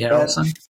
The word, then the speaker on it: Harrelson: It's like, and I'm like, wouldn't that just be kind of, there's this movie called The Champions with Woody Harrison, pronounce his last name. – Woody Harrelson.